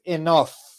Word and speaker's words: enough: Luego tenemos the word enough. Observen que la pronunciación aquí de la G y la H al final es como una F.